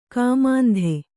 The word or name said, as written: ಕಾಮಾಂಧೆ